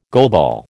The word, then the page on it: goalball: American English